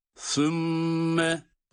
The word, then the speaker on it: Sümme: Sümme, evet, sa peltek değil mi? Sümme, mim şeddeli olduğu için tutuyoruz.